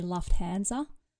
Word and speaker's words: Lufthansa: I do think we have the right answer, but I just want to correct the pronunciation. It's actually Lufthansa. – That is a misconception. – Yeah, I don't know. Like, some people just don't quite know how to pronounce things the German way.